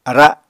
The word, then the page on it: ara: Apatani